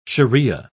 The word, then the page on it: shariah: British English